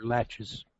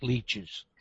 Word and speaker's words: laches: That's spelled L-A-C-S? – L-A-C-H-E-S. – L-A-C-H-E-S. – It might be L-E-C-H. Leches or latches. Not leeches.